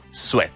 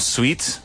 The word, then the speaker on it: sweat: Se escribe S-W-E-A-T, pero se pronuncia sweat. – Sweat. – Sweat. ¿Vale? Es como suéter. – Sí, exactamente. Es ¿vale? – como la primera sílaba.